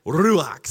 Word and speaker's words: Ruach: Ruach. Something like that. That's how you say it.